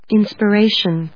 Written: inspiration

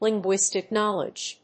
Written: linguistic knowledge

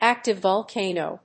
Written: active volcano

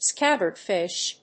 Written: scabbardfish

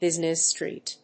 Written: business street

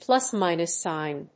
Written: plus-minus sign

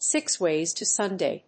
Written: six ways to Sunday

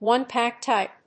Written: one pack type

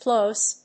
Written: ploce

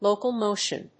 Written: local motion

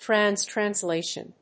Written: trans translation

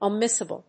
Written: omissible

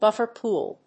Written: buffer pool

